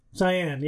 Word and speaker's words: Cyan: Cayan? – I don't know how to pronounce words. It's probably Cyan. – Yeah. Cyan, yeah. Blue. – Yeah. – Yeah. – Yeah. It's like a shade of blue.